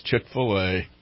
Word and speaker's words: Chick-fil-A: I mispronounced a word. You're right, Bad Bob. It's Chick-fil-A. I could swear it said Chick-a-fil. And I thought, that's a strange name. It's a strange name. But okay, it's Chick-fil-A.